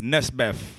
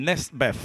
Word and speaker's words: Nesbeth: This one is Nefbet. Sorry, I pronounced it incorrectly. Nesbeth. – See me ya flash it and dash it like me got it. – Nesbeth. I can't pronounce the TH properly.